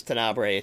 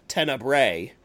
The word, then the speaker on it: Tenebrae: I got another one that I watched recently was Tenebrae, or as I'll always mispronounce it as Tenebrae. It should be Tenebrae.